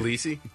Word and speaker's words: Tbilisi: Tbilisi, mm-hmm.